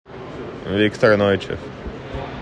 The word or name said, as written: Viktor Neuchev